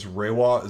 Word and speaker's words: Reiwa: It started on May 1st, and I think it's Reiwa.